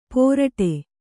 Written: ಪೊರಟೆ

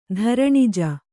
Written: ಧರಣಿಜ

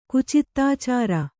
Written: ಕುಚಿತ್ತಾಚಾರ

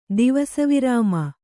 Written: ದಿವಸವಿರಾಮ

